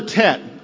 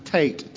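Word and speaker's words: Tate (טֵית): So this letter Tet or Tate, and now I will have the habit because I've been saying this quite often, but it's actually pronounced T-E-T, is actually pronounced Tate, T-A-T-E.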